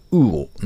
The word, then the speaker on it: UUUO: これをスマホアプリで発注できるプラットフォーム、ウーオ、 UUUO と書いて、ウーオなんですが、こちらをリリース。